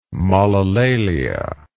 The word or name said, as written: molilalia